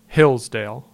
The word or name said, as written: Hillsdale